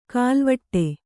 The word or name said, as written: ಖಲ್ವಾಟ